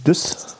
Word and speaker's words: Dusse: Ich spreche von meinem Tipp des Buches von Carsten Düss. Schreibt sich D-U-S-S-E, also französische Aussprache wohl eher.